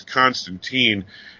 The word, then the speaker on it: Constantine: For those who don't know why we keep stressing teen and time, it goes back to the days of Alan Moore. He created the character, and then at one point he had somebody refer to him as Constantine, and he said Tyne rhymes with wine.